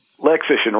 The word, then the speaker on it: Lexition: Did I get that Lexition. – Right? Consulting. – Lexition rhymes with magician.